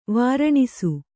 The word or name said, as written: ವಾರಾಣಸಿ